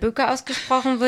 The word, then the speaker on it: Böker: Wichtig ist zu wissen, dass Steffi Böker, nicht Böcker, sondern Böker ausgesprochen wird, dass sie eine Instagram-Seite hat, dass ihr gerne schreiben könnt, ihr anrufen könnt im Laden und auch gerne vorbeikommen könnt.